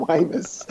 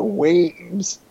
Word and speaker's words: Wemyss: well, so the thing is, I've always sort of pronounced Stan Wemyss, but he actually pronounced it Weems. Oh. Stan Weems.